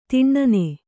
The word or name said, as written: ಧೂನನ